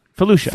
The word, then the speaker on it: Felucia: Felucia. – Felucia. – Felucia. – Like Felicia, but with an ooh.